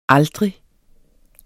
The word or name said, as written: aldrig